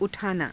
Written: u_thaanaa

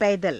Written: paidara

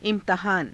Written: i^tahaana

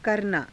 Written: karanaa

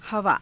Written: havaa

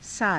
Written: saata

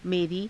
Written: merii

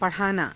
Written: pa_rhaanaa

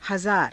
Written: hazaara

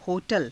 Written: ho_tala

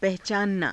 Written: pahacaananaa

